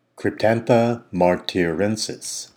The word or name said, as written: Cryptantha martirensis